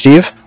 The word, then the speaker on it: Steve: My name is Steve. – Can you spell that? – Yeah, it's S-T-E-V-E. – S-T-E-V-E.